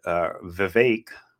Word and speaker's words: Vivek: And Vivek, rhymes with cake, Ramaswamy will be number three.